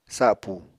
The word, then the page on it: sapu: apatani